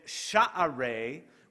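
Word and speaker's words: Sha'arei: Sha'ar in Hebrew means gate, with sha'are, which is the actual Hebrew pronunciation, but I don't say it that way either.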